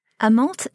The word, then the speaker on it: amant: And somebody was trying to explain the difference to me as you're talking about infidelity, and you're going to have to correct my pronunciation, the difference between the amant and the maîtresse. – Amante et maîtresse.